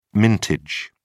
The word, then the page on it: mintage: British English